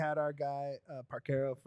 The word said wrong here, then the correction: Parcero: from Dupe by the River on there. – Philly Parcero. – Yeah, Parcero.